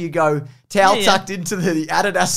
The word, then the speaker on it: Adidas: In Australia, we say Adidas. – We do. – Because it's the correct way to say it.